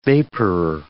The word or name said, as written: vaporer